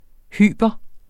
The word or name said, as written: hyper